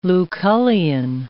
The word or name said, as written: Lucullian